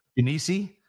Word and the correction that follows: Genesee: Is that how you say it? Genesee Brewhouse? – Oh, Genesee Brewhouse. Yeah, that's what did I say? – Genesi. I thought I was like, is that Italian place? No, Genesee Brewhouse.